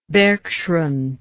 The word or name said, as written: bergschrund